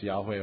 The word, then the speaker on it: Yahweh: Now, we think it's pronounced Yahweh, although the evidence for that is still somewhat dubious, I have to say. But Old Testament scholars still think that's the correct pronunciation of the divine name.